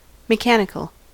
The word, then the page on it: mechanical: American English